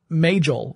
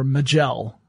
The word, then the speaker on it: Majel: And the project name at Google was, uh, uh, Majel or Magel, depending upon how you want to pronounce it, but Majel would be the, the way her name was actually pronounced.